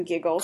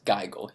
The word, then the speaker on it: Gigl: It's called The Ferryman's Institute by something Giggles. – Colin Giggles. G-I-G-L. It's pronounced Geigle, he says.